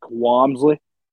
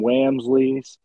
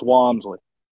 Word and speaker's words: Walmsley: Once the Walmsley happened, by the way, for Niagara region or anyone wondering with pronunciation, it's Rick Walmsley. I've heard some Wamsleys. It's Walmsley. Norfolk guy.